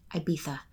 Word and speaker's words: Ibiza: And on that note, I'm also going to throw in Ibiza, or if you want to be douchey, Ibiza. And it's only douchey if you say that and you're American, by the way, because that's actually how it's pronounced.